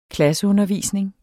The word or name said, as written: klasseundervisning